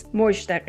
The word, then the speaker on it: Mozhdeh: Even though I butchered your name, uh, bad anthropologist, would you just say your name as it should be pronounced? – Yeah, it's Mozhdeh Gheini.